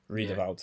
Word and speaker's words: Riedewald: Riedewald.